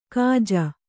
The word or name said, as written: ಗೂಜು